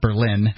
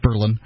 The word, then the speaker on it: Berlin: If you're looking for low cost, Berlin, New Hampshire, like the same as the German city, Berlin, but it's pronounced Berlin.